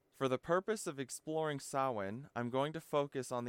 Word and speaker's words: Samhain: In short, the MH is kind of like its own letter, but they don't have the letter W, so we have the MH, and that's how you get Samhain from that spelling.